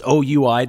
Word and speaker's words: Oui: We is, of course, a French word. I don't hold that against them. It's OUI Desserts at 3411 Kirby, and they make the best macaron in the city and also the best everything else.